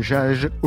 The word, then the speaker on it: Jaj: Jaj au Liban. Je ne sais même pas si c'est comme ça qu'on prononce. Très bien. Très bien, parfait.